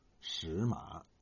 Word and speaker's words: 什么: 不读现代普通话读音，而是读它的本音，读作“ 石马”。